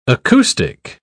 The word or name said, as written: acoustic